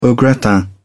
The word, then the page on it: au gratin: British English